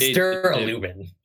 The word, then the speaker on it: Duralumin: Wait, is that how you say it? Is it not no. Duralumin? – It's Duralumin.